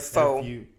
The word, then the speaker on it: faux: Made all the more effective by the by its transcriptions of Fox telephone recordings. – Yeah. – Did I say that right? – Faux. Faux. – Faux. That's stupid. – It's French.